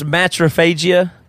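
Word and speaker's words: matriphagy: In the 70s, so the behaviors of regurgitation and matrophagy are not... How do you say it? – It's matrophagia, as in M-A-T-E-R, mater, and then phagia is to eat something.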